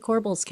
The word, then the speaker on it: corbel: I always thought it was corbels. Is it corbels? Corbels. I've been saying that wrong my whole life. – Galliber trees, corbels. I do say that. It's a corbel.